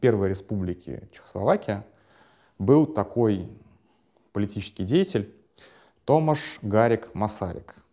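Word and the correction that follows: Garrigue: Гариг Масарик. Я его как Гарик, конечно, произношу, но он Гариг Масарик.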